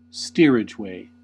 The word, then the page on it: steerageway: American English